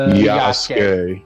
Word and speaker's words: Yasuke: I know the creator was working on Yosuke. – Yasuke. – Yasuke, yeah.